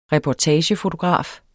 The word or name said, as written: reportagefotograf